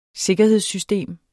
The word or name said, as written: sikkerhedssystemer